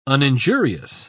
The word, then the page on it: uninjurious: British English